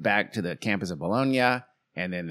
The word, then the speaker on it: Bologna: So Volta was at University of Pavia, and Galvani was at University of Bologna. And that's how you say it. – Is it Bologna? – Bologna. – Bologna. Sorry. God. – I'm saving you from the Italian wrath, okay?